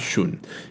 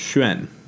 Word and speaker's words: Shun: Lastly, when talking about the last of the five emperors, which is Emperor Shun, as I said, it is not Shun. It is really phonetically pronounced Xuan, sort of a sh-u-n sound.